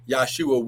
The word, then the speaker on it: Yahshua: Yahshua would be how his name would be pronounced in Hebrew because there is no J in the Hebrew language.